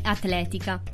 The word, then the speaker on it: atletica: Ad esempio, in atletica porrò l'accento grave sulla E ok? Quindi atletica.